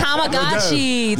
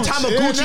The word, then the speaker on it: Tamagotchi: Nah, it's Tamagotchi. Tamagotchi. – Nah, we know what you think about the Gooch. – Nah, in Brooklyn, we pronounce it Tamagotchi, nigga.